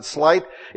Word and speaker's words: sleight: The sleight, the word sleight, or some people pronounce it slight, it means dice playing, throwing dice.